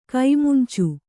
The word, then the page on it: ಕೈಮುಂಚು: kai muncu